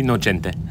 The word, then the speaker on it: Nocente: Can be Nocente.